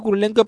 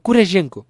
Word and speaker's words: Kurylenko: He dicho Kurylenko, pero está mal pronunciado. Es Kurylenko.